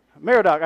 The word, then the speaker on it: Merodach: Somebody help me. Merodach, I said it right the first time.